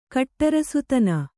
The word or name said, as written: ಕಟ್ಟರಸುತನ